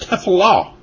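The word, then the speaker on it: tefillah (תְּפִלָּה): Here in verse 3, the word prayer is Strong's number 08605, and it's pronounced tefillah.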